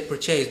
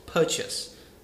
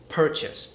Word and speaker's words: purchase: And also, um, like purchase, a lot of people say purchase, but actually it's pronounced purchase. That is a very common mistake. Not only do you need to pay attention to how a word is pronounced, you also need to pay attention to the main stress of the word. Like, um, I don't say purchase, I say purchase because the stress is on the first syllable.